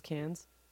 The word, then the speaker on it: Caen: Is it really not spelled, pronounced cans? – It's really not. – Fuck. – We've got to... It's spelled C-A-E-N. Can. – Oh, okay. I thought you meant C-A-N-N-E-S, cans.